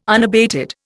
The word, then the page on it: unabated: Indian